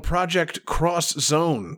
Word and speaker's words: Project X Zone: A little game called Project Cross Zone. Project X Zone. But it's pronounced cross if you're talking in, you know, Japan. So Project Cross Zone.